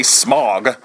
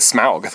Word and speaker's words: Smaug: You didn't say Smaug. You said Smaug, because that is his name.